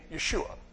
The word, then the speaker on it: Yeshu: By the New Testament Hebrew, this became Yeshua. So that was probably the name that he was given and that he was called. Although, in modern Hebrew, it's written and pronounced Yeshu.